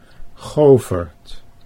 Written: Govert

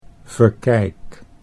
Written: Verkijk

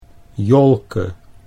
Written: Jolke

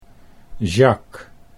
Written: Jacques

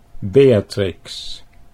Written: Beatrix

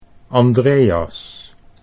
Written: Andreas

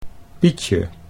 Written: Pietje

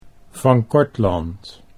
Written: Van Cortland

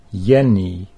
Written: Jenny

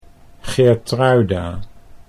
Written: Geertruida

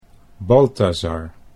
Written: Balthasar

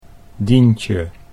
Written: Dientje

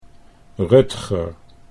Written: Rutger

